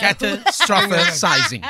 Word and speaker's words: catastrophising: And I need to get the pronunciation right. It's catastrophe, but say it with me, team. – Catastrophising. – There we go. Catastrophising. Did I get that right? – No. – Catastrophizing.